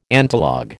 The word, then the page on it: antilog: American English